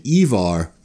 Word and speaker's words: Ivar: We do, but we're calling him Ivar, which is closer to the Old Norse pronunciation of the name, which is probably Ivar or Ifar or Ingvar.